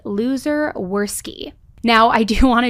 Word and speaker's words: Luzer: His name isn't Loser as in L-O-S-E-R. It is pronounced that way, but it is spelled L-U-Z-E-R. So as I'm saying his name throughout this, I'm not calling him a loser. That literally is just how you pronounce his name.